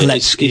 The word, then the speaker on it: Kalecki: Kalecki, is that how you pronounce it? – Kalecki is how you pronounce it in Polish, yeah.